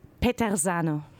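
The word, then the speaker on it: Peterzano: Petersano, wie spricht man das aus? Du bist italienisch? – Ich bin nicht italienisch. Petersano.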